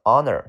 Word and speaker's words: honor: 下个单词 honor，这个 H 不发音，honor 叫荣誉啊，比如说这是我的荣誉来当你的老师，很荣幸，It's my honor to be your teacher。